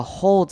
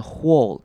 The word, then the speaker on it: whole: 好， whole 这个字要注意的是，不用把 w 的声音念出来，不用念成 whole，念成 whole，像洞就是地上的洞那种洞就好了。 whole 就是省略到 w 的声音，直接念 hole 的声音就好了。